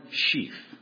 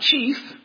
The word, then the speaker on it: Sheaf: Not chief, as in a city slicker might think, as if it was named after an Indian, but they were spelling it wrong and couldn't say it right. It is sheaf, because Warren is in the handle of the breadbasket.